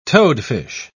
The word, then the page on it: toadfish: British English